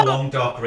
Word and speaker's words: Languedoc: It's from the Languedoc region in Vietnam. – No, it's Languedoc. Yeah, I think that's how you say it.